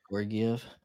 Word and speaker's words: Georgiev: Georgiev, if I said that right. – Yeah, Georgiev. Georgiev.